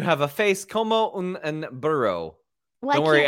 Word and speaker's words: burro: Say burro, like brrr with R.